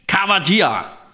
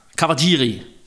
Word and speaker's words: Kawajiri: Jo, Yoshiaki, Yoshiaki, sådan må det være. Yoshiaki Kawajira. Kawajiri.